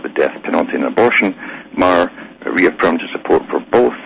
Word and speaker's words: Maher: I think it's Maher. I should actually pronounce it Maher or Maher. It's Bill Maher, it's pronounced.